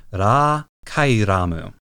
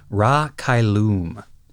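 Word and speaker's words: Ra Cailum: The Japanese is Ra Kairamu. I'm pretty confident, based on the way it's pronounced in Japanese and the way it's written, that this is derived from a classical Latin word for the heavens. Interestingly, the English dub for this movie pronounces the ship's name as Ra Kailum.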